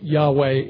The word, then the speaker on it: Yahweh: That's the correct, well, the best we know how to pronounce it, Yahweh in the Hebrew.